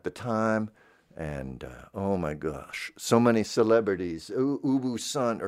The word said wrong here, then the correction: ubi sunt: or something, isn't that how you say it? Ubi Sunt.